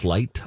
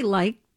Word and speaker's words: Licht: And the big CNN CEO, Chris Licht, I think is how you pronounce his last name, L-I-C-H-T. How would you say that? – I'd say Licht. – Licht. – Or Licht.